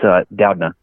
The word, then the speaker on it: Doudna: But okay, first, before we start, can you settle us how you pronounce your last name? We have a bet. – No, I've heard it a million different ways. I'm never offended anymore. I mean, I've heard it butchered. I've heard people stutter saying it, but it's Doudna.